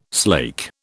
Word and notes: slake: British English

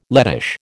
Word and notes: Lettish: American English

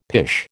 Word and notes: pish: American English

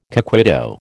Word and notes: coquito: American English

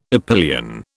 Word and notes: epyllion: British English

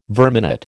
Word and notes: verminate: American English

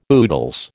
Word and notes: oodles: American English

